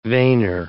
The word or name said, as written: veiner